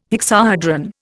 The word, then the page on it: hexahedron: Indian